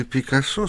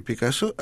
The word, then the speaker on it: Пикассо: В Барселоне есть прекрасный музей Пикассо, а точнее Пикассо. Когда, будучи в столице Каталонии, я по парижской привычке и по неосторожности произнес имя художника на французский манер с ударением на последнем слоге, как к тому привык, то тут же услышал нарекания в свой адрес, причем нарекания довольно жесткие.